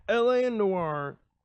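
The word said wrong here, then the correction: L.A. Noire: Let me read that again. I pronounced it really weird. – L.A. Noir? – L.A.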